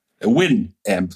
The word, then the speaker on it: Winamp: Winamp, so spricht man es aus.